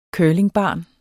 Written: curlingbarn

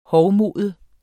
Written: hovmode

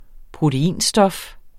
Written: proteinstof

